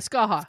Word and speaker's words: Skaha: And it's right near the, I'm going to mispronounce this, Saka Lake. Anybody know? – Skaha. – Skaha. Okay. Skaha Lake.